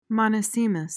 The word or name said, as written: monosemous